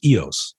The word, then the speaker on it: EOS: And it's pronounced EOS.